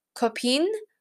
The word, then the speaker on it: Copines: So her song, Copine, which is spelled C-O-P-I-N-E-S, but in French, a lot of times the letters at the end are silent.